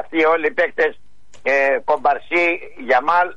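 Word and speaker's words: Yamal: Ο Κομπαρσί, ο Πέδρη και ο Λαμέγια. Συμφωνείς? – Ο Γιαμάλ λες.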